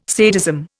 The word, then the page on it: sadism: Indian